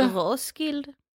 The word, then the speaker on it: Roskilde: Ska vi uttala det på danska bara för att? Eller ska vi... – Roskilde. – Roskilde.